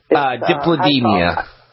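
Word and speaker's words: Dipladenia: Is it Diplett? Diplodemia? – Diplodemia. – Oh, thank you.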